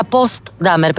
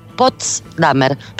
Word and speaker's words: Potsdamer: Sí, yo pensaba que era Potsdamer, pero es Potsdamer.